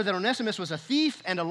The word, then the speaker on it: Onesimus: Philemon had a slave, Onesimus. It's not one Simus. You have to put the right emphasis in the right syllable. It's Onesimus.